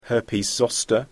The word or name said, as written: herpes zoster